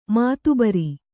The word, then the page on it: ಮಾತಬರ: mātabara; Kannada